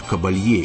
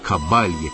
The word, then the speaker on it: Кабалье: Двенадцатого апреля исполняется 75 лет одной из крупнейших оперных певиц XX века Монсеррат Кабалье, или Кабалье, как ее произносят.